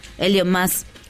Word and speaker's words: Elon Musk: Elon Musk.